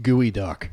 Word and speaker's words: geoduck: So we've got smoked salmon, smoked octopus, and smoked gooey duck, which is basically a dick clam for anybody that has ever seen one. Look up gooey duck. It's spelled geoduck, but it's don't look up dick clam.